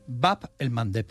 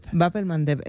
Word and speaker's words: Bab el-Mandeb: Bab el-Mandeb. – Bab el-Mandeb, esa me la voy a apuntar. – Cuesta un poquito pronunciarlo, Bab por eso en Occidente se dice el Mandeb. – Bab el-Mandeb.